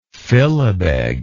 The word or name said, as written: filibeg